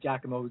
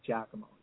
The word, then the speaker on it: Giacomo: That's why I use Jack, because it's Giacomo, Jack. It's easy. – Yeah, even though it's spelled G-I-A, it's Ja sound, so it comes out like Giacomo.